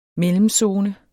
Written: mellemzone

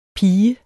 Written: pige

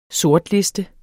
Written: sortliste

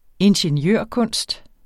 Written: ingeniørkunst

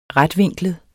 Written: retvinklet

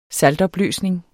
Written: saltopløsning

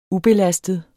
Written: ubelastet

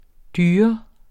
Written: dyr

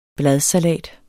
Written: bladsalat

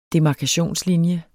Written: DEMARKATIONSLINJES

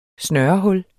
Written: snørehul

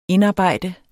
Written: indarbejde

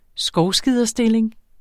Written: skovskiderstilling

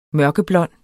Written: mørkeblond

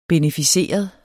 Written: beneficeret

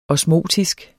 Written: osmotisk

